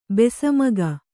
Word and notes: ಬಸಿಮೂಗ: kannada

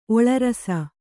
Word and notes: ಒಳರಸ: oḷarasa

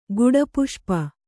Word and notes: ಗುಡಪುಷ್ಪ: guḍa puṣpa

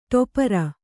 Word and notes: ಟೆಪ್ಪರ: ṭeppara

